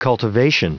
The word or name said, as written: cultivation